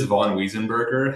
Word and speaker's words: Von Wiesenberger: It is Von Wiesenberger. However, anyone who's not in a Germanic country would say it exactly the way you said it.